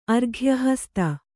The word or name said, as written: ಅರ್ಘ್ಯಹಸ್ತ